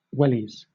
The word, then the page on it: wellies: British English